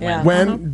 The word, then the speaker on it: Nguyen: No, it's N-G-U-Y-E-N. Nguyen? – You pronounce that Wen.